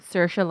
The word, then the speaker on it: Saoirse: Saoirse like inertia.